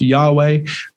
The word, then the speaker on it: Yahweh: sometimes pronounced Yahweh.